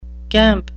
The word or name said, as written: gamp